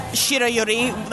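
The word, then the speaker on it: shirayuri (白百合): So it's like shirayuri. Like instead of like shirayuri, you don't stress that last syllable. You say it all in one.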